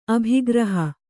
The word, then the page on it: ಅಭಿಗ್ರಹ: abhigraha